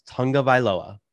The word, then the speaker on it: Tagovailoa: It is pronounced Tagovailoa. Watch an interview with him and how he pronounces his last name. It is Tagovailoa.